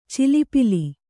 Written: ಚಲ್ಲಪಿಲ್ಲಿ

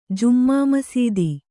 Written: ಜುಮ್ಮಾಮಸೀದಿ

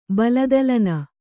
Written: ಬಲದಲನ

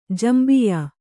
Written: ಜಂಬಿಯಾ

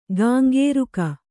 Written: ಕೆಂಕರಿಕೆ

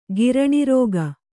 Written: ಗಿರಣಿರೋಗ

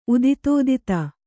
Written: ಉದಿತೋದಿತ

